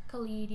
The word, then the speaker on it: Khalid: It's OTW by Khalid. – Khalid, yeah.